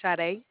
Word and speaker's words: Sade: My name is Sade, and it's spelled S-A-D-E.